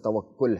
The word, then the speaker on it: توکل: دیکھیں، صحیح تلفظ توکل ہے۔